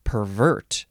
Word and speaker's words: pervert: Three, uh, the synonym is just pervert, or actually, in this context, it would be pronounced pervert, as in pervert justice.